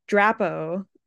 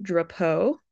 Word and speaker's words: Drapeaux: My Native American family, they say Drapeaux. And my dad really didn't like that, I guess, and he wanted me to say it Drapeaux.